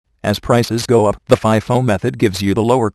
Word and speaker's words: FIFO: Pronounced FIFO, the company assumes that the first item making its way into inventory is the first sold.